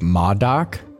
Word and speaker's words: MODOK: There's been a lot of screenshots I've seen of MODOK.